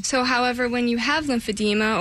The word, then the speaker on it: lymphedema: I've never heard of lymphedema, which is good that you're here. Now we'll know what it is. Am I saying that correctly, first of all? – It's lymphedema. – Oh, there you go.